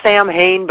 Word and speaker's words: Samhain: It's spelled out like Sam Hain, but it's pronounced Samhain.